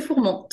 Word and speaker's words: Fourman: Au début, je, disais Fourman, mais non. non, c'est Fourman, tout à fait.